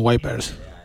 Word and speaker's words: Wipers: Bueno, yo en Estados Unidos no he escuchado a nadie decir Vipers, la verdad. O sea, así que me quedo con Wipers.